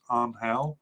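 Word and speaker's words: Ángel: I know it's supposed to be pronounced Angel.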